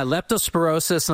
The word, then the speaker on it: leptospirosis: Yeah, leptospirosis, and I talked about this before the break because it was a problem in Jamaica. Now, this outbreak happened in 2021. But it has like a second story. Leptospirosis is a bacteria.